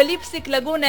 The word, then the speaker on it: Lipstick Lagoon: Dunque, Lipstick Lagoon è laguna di Rossetti.